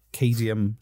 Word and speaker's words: cadmium: Zinc cadmium sulfide. Am I saying that right? C-A-D-M-I-U-M. Cadmium.